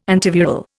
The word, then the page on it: antiviral: Indian